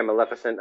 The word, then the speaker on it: Maleficent: Maleficent? – Yeah, Maleficent.